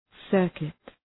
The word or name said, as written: circuit